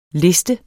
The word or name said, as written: liste